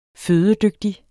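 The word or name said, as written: FØDEDYGTIG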